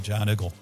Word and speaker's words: Giant Eagle: back in Western Pennsylvania days, you'd go to the Giant Eagle. – Okay. – It's a giant eagle, but for some reason they all pronounce it John Eagle.